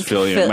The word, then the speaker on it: Filion: They always want to pronounce it Filion or Phalion or Filion. You know, it's Filion. My dad would say Filion. – Mm-hmm. And so rhymes with million.